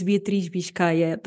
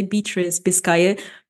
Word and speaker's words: Beatriz Biscaia: So yeah, like literally my name is Beatriz Biscaia. That's the literally the Portuguese name, the Portuguese way of pronouncing it. But normally I say Beatriz Biscaia because it's, you know, a little bit easier to pronounce.